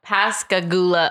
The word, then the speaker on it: Pascagoula: So I am going to be talking about the Pascagoula abduction, and I'm very sorry if I'm saying that wrong.